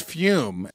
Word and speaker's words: Fume: Let's take a quick second and thank our newest sponsor, which is Fume. That's spelled F-U-M, pronounced Fume.